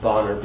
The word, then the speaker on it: Bonar: Horatius Bonar, or I've been told that's actually pronounced Bonar, B-O-N-A-R, Horatius Bonar.